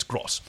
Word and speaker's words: Guy Gross: Oh, there's a commentary track on the DVDs and it's by Guy Gross, the composer, whose name I've been mispronouncing. It's not Gross, it's Gross.